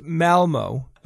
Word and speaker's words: Malmö: Well, first, it's pronounced Malmo.